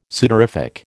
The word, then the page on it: sudorific: American English